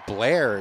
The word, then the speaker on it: Blair: I just wanted to let you know our friend Blair is here, and her name rhymes with Claire.